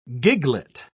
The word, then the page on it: giglet: British English